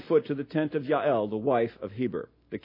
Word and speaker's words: Yael: And he came to the tent of a woman named Yael. You'll forgive me pronouncing the J as a Y, but Yael, Heber's wife.